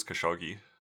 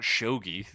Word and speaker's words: Khashoggi: I always assumed it was Khashoggi. – Me too. It's two G's, right? Like, yeah, it is. – It's not Shogi.